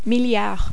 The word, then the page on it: milliard: French